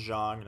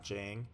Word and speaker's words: Zhang: I learned it's pronounced Zhang, not Jang.